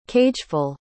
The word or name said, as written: cageful